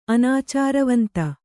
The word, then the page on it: ಅನಾಚಾರವಂತ: anācāravanta